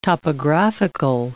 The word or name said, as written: topographical